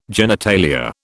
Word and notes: genitalia: British English

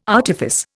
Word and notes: artifice: Indian

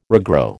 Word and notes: regrow: American English

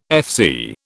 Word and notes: FC: British English

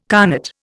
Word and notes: connate: Indian

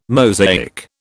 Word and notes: Mosaic: British English

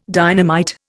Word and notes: dynamite: Indian

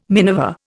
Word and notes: miniver: Indian

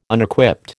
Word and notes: Unequipped: American English

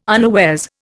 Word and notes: unawares: Indian

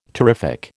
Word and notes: terrific: American English

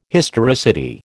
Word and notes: historicity: American English

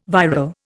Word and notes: viral: Indian

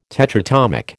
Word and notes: tetratomic: American English

